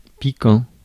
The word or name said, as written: lurid